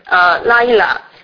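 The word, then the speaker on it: laila (לַיְלָה): 这个黑暗这个希伯来文，它的读音是 chosek，它这个夜晚它的读音是 laila。就是他，你听到希伯来人读的时候，你会觉得黑暗的是那种特别短促急促的发音，但是这个夜晚是那种是长音，非常平和的。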